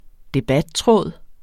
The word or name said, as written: debattråd